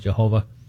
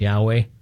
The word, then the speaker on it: Yahweh: Some people call it Jehovah. A better pronunciation is Yahweh.